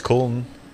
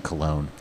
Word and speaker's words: Köln: Kolsch is Köln. – Yeah, Cologne. And how do you say it? – Köln. Yeah. – Or is it Cologne? – That's the American one.